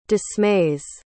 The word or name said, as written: dismays